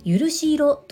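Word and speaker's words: 聴し色: この形状の蝶に色彩の色という字を書いて許し色と読むそ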